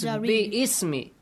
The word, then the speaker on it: bismi: So, for example, bismi rabbika alladhi khalaq. Bismi. It is bi-ismi. If you do not pronounce that ba, you have to put a kasra at the bottom of alif and you say ism.